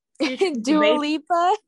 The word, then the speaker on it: Dua Lipa: And the last one is Levitating by Lua Lipi Lipa. – Dua Lipa. – This is so weird. But anyways, yeah. Dua Lipa. Yeah, Lua Deep Lipa. Lua Lipa. Dua Lipa. Am I saying that right? I don't know. Yes, ma'am. Dua Lipa.